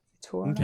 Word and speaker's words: Victorinox: Victorinox.